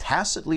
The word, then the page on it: tacitly: American English